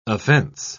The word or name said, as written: offense